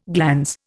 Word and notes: glans: Indian

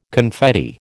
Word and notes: confetti: American English

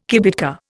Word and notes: kibitka: Indian

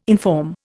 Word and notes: inform: Indian

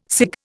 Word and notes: cig: Indian

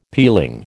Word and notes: peeling: American English

